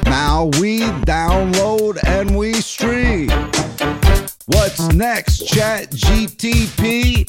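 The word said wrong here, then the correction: ChatGPT: Tunes. Did I say it right? – No. – So close. – It's GPT.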